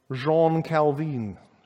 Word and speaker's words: Jean Calvin: Jean Calvin. It'd probably be the better way to say his name.